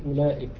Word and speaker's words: أولئك: وهناك حروف تكتب ولا تنطق كالواو التي بعد الهمزة في أولئك.